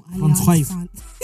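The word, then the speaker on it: Alliance Française: Is that how you pronounce it, Alliance Francaise? – Yes. – It's not Alliance France. – No, Alliance Francaise. No, it's Alliance Francaise.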